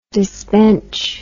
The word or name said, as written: disbench